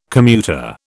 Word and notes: commuter: British English

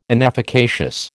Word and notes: inefficacious: American English

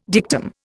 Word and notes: dictum: Indian

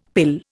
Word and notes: pill: Indian

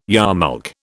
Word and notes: yarmulke: British English

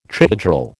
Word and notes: trihedral: American English

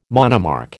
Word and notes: monomark: American English

